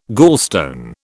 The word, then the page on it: gallstone: British English